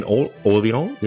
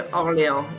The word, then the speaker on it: Orléans: You grew up in Orléans, am I saying that correctly? – Yes, near Orléans, yes.